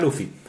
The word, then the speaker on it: Luffy: Em, básicamente por una cosa de romantización que están haciendo ahí, pero al final es como el término de Luffy, que se escribe Ruffy, pero se pronuncia Luffy.